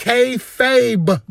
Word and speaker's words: kayfabe: It's not fable. It's kayfabe.